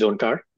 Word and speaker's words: Onkar: So my name is Onkar, and I lead the data science and AI teams at Turing.